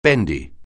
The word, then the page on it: bendy: anglais